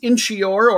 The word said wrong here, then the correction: Inchicore: or Inchicore, rather, Dublin.